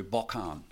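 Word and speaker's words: Bochan (בֹּחַן): And that's pronounced in the Hebrew, Bochan.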